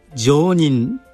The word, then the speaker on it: 成人: 仏教ではこの成人を常人と読みます